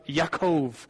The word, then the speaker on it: Yaakov: near a field of Yaakov, Jewish pronunciation of Jacob.